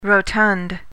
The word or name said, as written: rotund